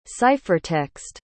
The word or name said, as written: ciphertext